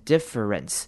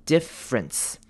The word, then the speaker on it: difference: Difference 呢，这边虽然拼法是 D-I-F-F-E-R，但是呢，我们通常会把 E-R，就是中间那个 E-R 的声音省略，所以我们可能不会念成 difference，我们会念成 difference。 Difference 就是把 E-R 省略成 R 的声音就好了。